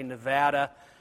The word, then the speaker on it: Nevada: enlighten people on how to pronounce Nevada. And the number is growing. The number is growing. And so there are more and more people who are learning to say Nevada instead of the other wrong pronunciation.